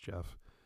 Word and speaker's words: Geoff: I always get confused on how to say someone's name when I see G-E-O-F-F. But I think it's just pronounced Jeff.